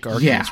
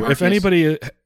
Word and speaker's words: Arceus: Thank Arceus, rather. – Oh, yes. Thank Arceus. Yes, and yes, I realize we both say Arceus in different ways. I say Arceus. – We do. – You say Arceus.